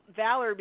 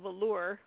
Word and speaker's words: Valor: But I've heard marjoram called majorum, oregano called oregano, valor being called velour.